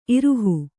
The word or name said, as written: ಇರಹ